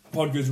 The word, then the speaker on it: Pogba: I tweeted something and said, Pogba's run the show. I keep saying his name wrong, sorry. Pogba. Pogba's run this show.